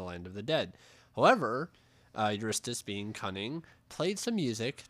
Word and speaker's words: Eurystheus: So one thing that I have here is the story of Eurydice? – Huh? – Eurystheus. Oh, Eurystheus? I said it right the first time. I don't know why I'm struggling. Yeah. Eurystheus.